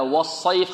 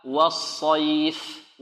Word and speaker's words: والصيف: طيب، إذن نطق هكذا، والصيف خطأ و، وكذلك والصيف و خطأ، وكذلك والصيف بضم الشفتين خطأ. إذا النطق الصحيح بإذن الله تعالى هكذا، و والصيف والص والصيف.